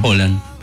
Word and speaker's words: Haaland: Y que también se dice así en realidad. – Sí, se dice así, bueno. – Haaland.